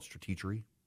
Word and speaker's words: Stratechery: He writes a website, Stratechery. I think most people pronounce it Stratechery, but he wants to call it Stratechery.